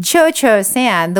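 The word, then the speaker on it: Cio-Cio-San: Well, you know what's so interesting to me is Cho Cho San, the way it's spelled, C-I-O-C-I-O, is actually an Italian way of writing Cho. In Japanese, it's C-H-O-C-H-O. It would be C-H-O. Cho Cho would be C-H-O.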